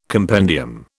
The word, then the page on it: compendium: British English